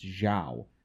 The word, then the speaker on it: Zhao: First of all, I'm pretty sure it's pronounced Zhao.